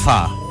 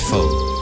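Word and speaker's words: phở: Hey, Don't Tell Ish says PHO is actually pronounced as fa, not fo.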